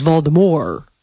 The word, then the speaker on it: Voldemort: that the T is silent in Voldemort. We all say Voldemort, but the way she pronounces it is Voldemort. Like the T, you leave it off.